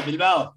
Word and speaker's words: Bilbao: I don't think I ever pronounced that one right. – That's okay, don't worry, most people don't. But yeah, Bilbao. That's where I am. That's good. That's very, very good.